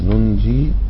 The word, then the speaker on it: ننج: فنقول كذلك حقا علينا ننج، إذا وقفت عليها كذلك حقا ننج، وننجي المؤمنين.